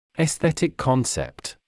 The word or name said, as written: esthetic concept